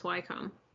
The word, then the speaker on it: Wycombe: Yeah, I think it's pronounced Wycombe. – In Buckinghamshire. – Oh, yeah, Buckinghamshire, yeah. – Yeah, and that's actually the county I got married in. – And that's what the house is called, too. – Yeah. Actually, it might be pronounced Wickham now that... Oh, I don't know, because I think there is, like, a W-I-C-K-H-A-M, like in Pride and Prejudice.